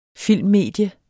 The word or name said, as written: filmmedie